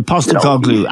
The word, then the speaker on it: Postecoglou: Yeah, Postecoglou.